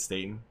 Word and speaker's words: Staten: Staten? – Mm-hmm.